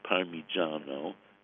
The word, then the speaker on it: Parmigiano: You know, by the way, the correct pronunciation is Parmigiano, and then the Reggiano.